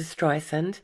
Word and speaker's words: Streisand: Okay, well, according to Streisand, her surname is pronounced with an S sound, like sand on the beach, not the Z sound often used.